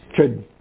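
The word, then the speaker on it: Chöd: C-H-O-D. Come again? – Chud. – Yeah, in English we say chud or chod. North Carolina, chud. – Yeah. – But it's the D is silent. It just shortens the O.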